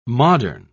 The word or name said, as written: modern